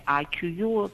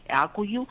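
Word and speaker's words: Akkuyu: Στην είδηση και στην κατάθεση του θεμέλιου λίθου αυτού του πυρηνικού εργοστασίου, εμείς εδώ το λέμε Ακουγιού, προφέρεται και Ακουγιού,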